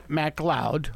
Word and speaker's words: MacLeod: Okay, Gavin McLeod, pronounced MacLeod, like I just said, was born Alan George C.